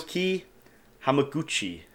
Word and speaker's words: Naoki Hamaguchi: Naoki Hamaguchi.